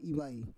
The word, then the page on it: ivai: Roon